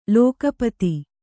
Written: ಲಘೂಪದೆ

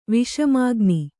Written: ವಿಷಮಾಗ್ನಿ

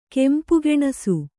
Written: ಗಾಂಪುಗಾಣಿಸು

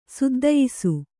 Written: ಸುದ್ದಯಿಸು

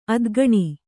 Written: ಅದ್ಗಣಿ

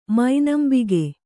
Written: ಮನಂಬುಗು

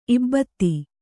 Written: ಇಬ್ಬತ್ತಿ

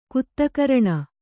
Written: ಕುತ್ತಕರಣ

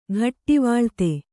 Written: ಘಟ್ಟಿವಳ್ತಿ

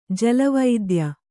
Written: ಜಲವೈದ್ಯ

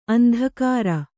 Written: ಅಂದಗಾಱ

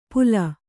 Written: ಪುಲ

